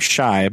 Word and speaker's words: Shiey: It's just his name, Shy, but it's spelled Shiey.